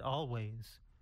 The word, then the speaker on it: Alvvays: Always. Always is spelled A-L-V-V-A-Y-S.